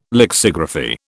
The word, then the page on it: lexigraphy: British English